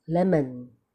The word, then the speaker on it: lemon: 比方这个字叫 lemon。所以那个门门就是 schwa， taken taken ken 是 schwa， pencil pencil s o o 是 schwa， all right， circus circus OK 都是 schwa。